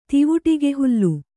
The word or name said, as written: ತಿವುಟಿಗೆಹುಲ್ಲು